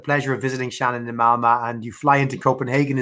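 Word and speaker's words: Malmö: place called Malmo. – Malmo, is that the correct pronunciation? – Malma, yeah. – Ma, Malma uh,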